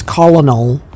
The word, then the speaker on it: colonel: So I looked up one day, why is colonel pronounced colonel? – Oh, yeah. – And the answer is because it's stupid. And it's basically the accent. It used to be like colonel. – Yeah. – But because they were British, it was like colonel.